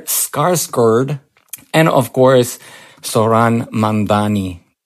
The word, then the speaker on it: Mamdani: The name is Mamdani, M-A-M-D-A-N-I. You should learn how to say it.